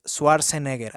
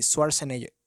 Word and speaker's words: Schwarzenegger: Según yo es Arnold Schwarzenegger, así, Schwarzenegger, Schwarzenegger, así.